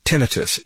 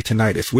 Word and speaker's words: tinnitus: You say tinnitus. Which is the correct pronunciation? – Both pronunciations are good. It's like President Bush called it nuclear and some people called it nuclear. So take your choice.